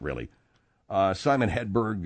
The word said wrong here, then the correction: Helberg: Helberg, I mean, and... – yeah, he's still friends with him. You got his name wrong. – Helberg.